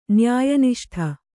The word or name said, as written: ನ್ಯಾಯನಿಷ್ಠ